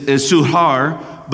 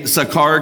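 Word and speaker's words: Sychar: The actual pronunciation is Suhar, but we say Sakar because it's just easier.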